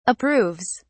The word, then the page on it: approves: British English